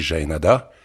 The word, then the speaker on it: Jaenada: Mais quand on me demande la manière de le prononcer, moi je dis Jaenada,